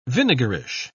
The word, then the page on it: vinegarish: British English